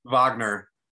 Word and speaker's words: Wagner: How's it pronounced? – Wagner. – The V. – Wagner, yeah. – Act Wagner. – Like it's a V. – I call them V-O-G.